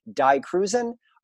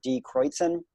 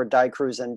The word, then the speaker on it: Die Kreuzen: But I think a fair band to compare them to is the Touch and Go band, whose name is spelled, and I always pronounced it like this when I first got into them, Die Cruisen. But I think purists would pronounce it Die Kreuzen. So I think that Die Kreuzen and or Die Cruisen, depending on how much of a purist you are, and Husker Du, they don't exactly sound alike.